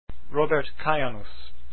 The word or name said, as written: kajanus